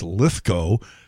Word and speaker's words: Lithgow: John Lithgow, and that is the correct pronunciation. It's Lithgow.